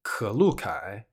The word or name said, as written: 可露凯